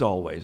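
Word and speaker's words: Alvvays: That's Always, A-L-V-V-A-Y-S, a little spelling. – Challenge. – It's not yeah, Always. Everybody wants Always, but, you know, yeah. It's Always. That's the way they pronounce it.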